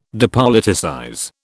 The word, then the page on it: depoliticize: British English